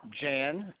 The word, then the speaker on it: Jan: Jan, J-A-N, and the last name's Phillips, P-H-I-L-L-I-P-S.